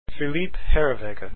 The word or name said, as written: herreweghe